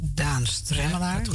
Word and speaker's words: Daan Stremmelaar: Marjan Vink zit aan tafel en Daan, zeg ik het goed? Daan Stremmelaar. – Ja.